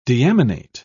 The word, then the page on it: deaminate: British English